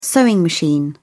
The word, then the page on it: sewing machine: British English